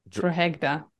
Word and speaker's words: Drogheda: How do you say it? – Drogheda. – Drogheda.